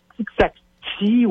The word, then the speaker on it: Tixati: OK, T-I-X-A-T-I, je pense que c'est T-I-X-A-T-I. Va sur Google, là, je l'ai peut-être mal prononcé. Là, Tixacti? – Tixacti, ouais, ça ressemble à taxi, mais c'est pas taxi, tu sais.